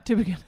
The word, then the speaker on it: Tübingen: You traveled to Germany and went to in Tübingen. You went to That's good pronunciation. Yeah. – Is it right? – Yeah. Tübingen. – Yeah, I thought it was right.